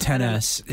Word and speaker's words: XS: 10S. Yeah, it's pronounced 10S.